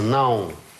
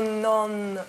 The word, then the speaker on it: não: Частица «не» по-португальски выглядит таким образом. Читается «нау». – «Нау». – «Нау» в нос.